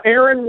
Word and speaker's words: Aron: Aaron Worthing. – Aaron Worthing. – And it's spelled A-R-O-N, because despite the fact it's a biblical name, almost nobody spells it right.